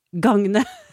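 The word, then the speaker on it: gagne: Det er et verb. Uttales gangne. En variant er gavne. Og etymologisk kommer det fra norrønt gangna, avledet av gagn.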